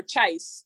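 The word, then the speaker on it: Cześć: Like, when my teammates walked into the locker room, Jindobre, like, or Chais. Chais is how you say hello. Chais, Chais, Chais, which is pretty easy.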